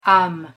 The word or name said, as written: ame